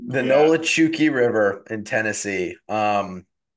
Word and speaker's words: Nolichucky: I'm, look, I'm looking it up right now, and it Looks like Nolichuki. Nolichuki.